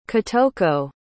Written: Kitoko

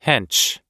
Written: hench

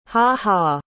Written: ha-ha

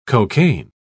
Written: cocaine

